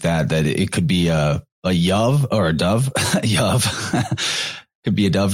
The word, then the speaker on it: dubh: Well, yeah, like a dove in the Celtic means black. It would be spelled D-U-B-H. They pronounce that B-H like a V, I believe.